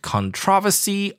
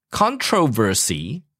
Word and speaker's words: controversy: That word, controversy or controversy, both okay. You can pronounce it both ways. Controversy or controversy, both okay. – Mm-hmm. – Yes. 所以有争议的话呢，就是我们讲的 controversy or controversy. 那两个发音就是一个是英式的，一个是美式的。 – Oh, okay. – That's all. So one is British, one is American.